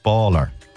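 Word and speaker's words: Bálor: So if there's a father on the A, it's pronounced Baller.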